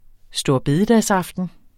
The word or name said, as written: storebededagsaften